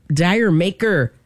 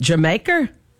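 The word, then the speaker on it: D'yer Mak'er: Dire Maker. That's how it's spelled. But really, you're supposed to read it Jamaica.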